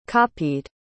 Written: copied

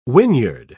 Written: whinyard

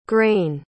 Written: graine

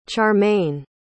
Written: charmayne

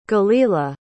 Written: galila

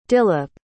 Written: dilip

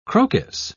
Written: croquis

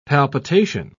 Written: palpitation

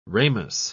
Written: ramus

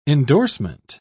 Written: endorsement

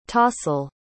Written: tahsil

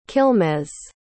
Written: quilmes

